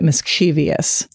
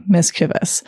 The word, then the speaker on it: mischievous: See, this one I know is tricky for people, partly because many people, including my dad, pronounce it mischievous. – Mm-hmm. – But I feel like once you know how it's pronounced, it's easier to spell. So I'm going to, okay, mischievous. M-I-S-C-H-I-E-V-O-U-S, mischievous.